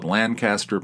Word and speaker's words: Lancaster: Lancaster, Pennsylvania, right? Except they don't pronounce it that way. They pronounce it like Lancaster.